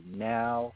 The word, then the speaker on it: NAO: Yeah, but when you look at a lot of these artists, like Now, pronounced N-A-O, look her up.